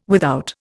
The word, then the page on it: without: Indian